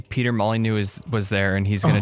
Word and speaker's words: Molyneux: It's Molyneux. The X is silent.